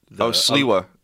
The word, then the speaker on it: Sliwa: It was Sliwa with a W. Because in Polish... – Sliwa. – Yeah, but you pronounce it with a V.